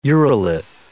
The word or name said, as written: urolith